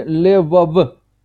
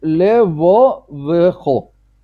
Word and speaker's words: левовехо: Можно произнести Левов, не произнося, не делая никакого «э». Правильно произносить Левовехо. Короткое должно быть «э».